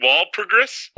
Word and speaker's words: Walpurgis: Is that really Walpurgis? – Walpurgis, you said it right the first time.